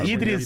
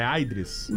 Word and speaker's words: Idris: Falei errado o nome dele, será? É Aidris. Pode dizer.